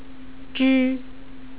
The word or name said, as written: zhi